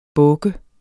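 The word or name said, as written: båke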